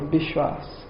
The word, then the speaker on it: Bishwas: Vishvas is the word, but in Bengali it's Bishwas. So in the text it may say Vishvas or Bishwas. Or a lot of the A sound in Bengali is pronounced as O.